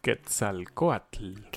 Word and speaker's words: Quetzalcoatl: Quetzalcoatl.